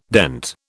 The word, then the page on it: dent: British English